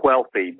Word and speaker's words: Whealthy: The title is Becoming Wealthy, W-H-E-A-L-T-H-Y. It's a blending of wealthy and healthy. And if you want to know how to pronounce it, if you're familiar with Family Guy, it's the same way Stewie pronounces Wheatins and Cool Whip.